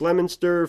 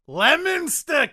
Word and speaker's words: Leominster: So I believe it's Leominster, Fitchburg, and Winchington. Pardon for not pronouncing these places correctly. – It's pronounced Leominster, kid.